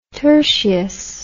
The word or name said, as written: tertius